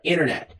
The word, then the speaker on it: Internet: A gente simplesmente, os americanos muitas vezes, simplesmente engolem esse T e fica Internet.